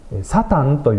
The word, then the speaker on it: サタン: で、訴えるものというのを旧約聖書のヘブライ語の言葉でサタンというふうに発音するそうです。